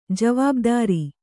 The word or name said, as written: ಜೀವಭದ್ರ